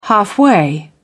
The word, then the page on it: halfway: British English